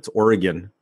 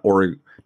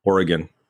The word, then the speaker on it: Oregon: But and for all the listeners out there, it's Oregon, not Oregon, Oregon. And our producer has family out there in Oregon, and that's how it's pronounced.